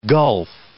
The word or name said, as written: gulf